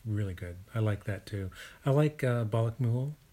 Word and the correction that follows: Balamku: Did I say that right? – Balamku? – Balamku. Thank you.